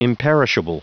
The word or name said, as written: imperishable